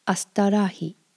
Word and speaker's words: astârâhi: Tata kita astarahi.